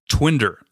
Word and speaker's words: Twindr: Twinder. This is spelled odd. T-W-I-N-D-R. It's kind of like Twitter and Tinder.